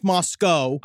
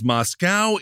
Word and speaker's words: Moscow: It is pronounced Moscow. – I did not realize that. I thought it was Moscow.